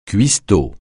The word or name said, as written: cuistot